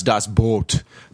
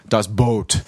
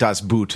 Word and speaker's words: Das Boot: My college girlfriend who spoke German insisted it was pronounced Das Boot. Das Boot. You know, and not Das Boot the way it's spelled.